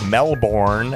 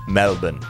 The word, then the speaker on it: Melbourne: It's not Melbourne, as we say in the United States. Down there, they say Melbourne.